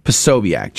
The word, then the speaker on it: Posobiec: How do you say his last name? – I think it's Posobiec. – Posobiec. Jack Posobiec.